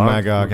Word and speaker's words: Magog: In Kingdom Come, he gets killed by, I don't know how to pronounce his name, Magog? – Magog? Oh, Magog, yeah.